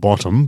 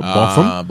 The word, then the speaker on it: botham: And botham, not bottom, but botham. – Botham. – Even though it's spelled bottom now, which means valley bottom.